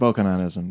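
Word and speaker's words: Bokononism: Okay, so we should say at the outset, a fake religion called Bokkanonism, how do you pronounce it? – I pronounce it Bokkanonism, yeah.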